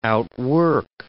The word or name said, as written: outwork